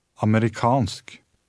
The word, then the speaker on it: amerikansk: In this word, the stress is on the last syllable. – Amerikansk.